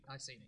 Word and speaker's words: Iceni: And of course, as far as the Iceni or Iceni or the Iceni, however you want to pronounce it, how should we pronounce it? – Iceni. We'll stick on one.